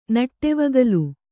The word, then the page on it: ನಡೆವಗಲ್: naḍevagal